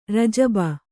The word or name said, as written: ರಜಬ